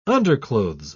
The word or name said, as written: underclothes